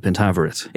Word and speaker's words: Pentaverate: Is that how you say it? – Yes. The Pentaverate.